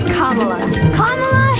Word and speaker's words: Kamala: It's Kamala Harris. – Yeah, we know. We just want to make sure we say it right.